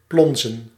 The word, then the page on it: barboter: France; [baʁ.bo.te]